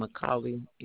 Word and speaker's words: McColley: It's spelled as K-I-R-B-Y. Last name, Macaulay, M-C-C-O-L-L-E-Y.